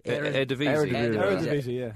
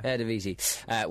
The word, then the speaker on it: Eredivisie: Eredivisie. – Eredivisie.